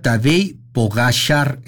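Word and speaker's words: Tadej Pogačar: Dice, se pronuncia Tadej Pogacar en vez de Tadej Pogacar.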